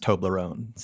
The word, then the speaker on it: Toblerone: Michael, it's pronounced Toblerone.